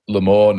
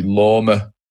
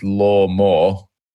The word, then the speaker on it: Lormor: I just call it Lamore now because loads of people call me that for some reason, whereas they didn't used to. It used to be Lorma. And then, obviously, if you say it how it's spelled, it's Lorma, which is L-O-R-M-O-R.